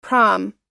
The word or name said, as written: prom